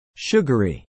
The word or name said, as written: sugary